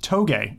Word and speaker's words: touge: I actually queued that up to show people, and it's pronounced toge. It's a form of racing. I've pronounced it Touge for the longest time as well.